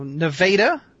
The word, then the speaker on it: Nevada: Nevada is how you say his town, because there's a Nevada in Missouri.